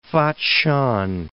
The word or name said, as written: Fatshan